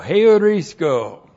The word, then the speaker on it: heurisko: H-E-U-R-I-S-K, long O, heurisko!